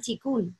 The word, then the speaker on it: Qigong: No he visto en mi vida esa palabra escrita porque de hecho se escribe, también se puede decir Qigong, ¿cierto? Chikun es